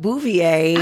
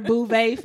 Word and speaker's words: Beauvais: Yeah, so Garcelle Bouvier. – I did not know that's how you pronounce her name. I've called her Bouvet for so long.